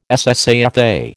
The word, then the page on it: SSAFA: American English